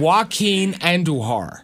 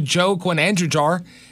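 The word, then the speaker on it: Joaquín Andújar: He didn't play the Braves or whatever, so I didn't know how to say it. So when I'd see his baseball card to say, hey, I got a new Joe Quinandra jar if you want to trade for it.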